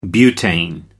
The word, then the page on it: butane: British English